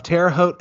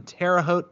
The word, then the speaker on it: Terre Haute: Our special guest today is operator Evan Williamson of Terre Haute FSR in Terre Haute, Indiana.